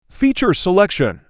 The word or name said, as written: Feature selection